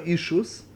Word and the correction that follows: Eshus: Eshus, really, it's pronounced.